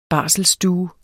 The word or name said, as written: barselsstue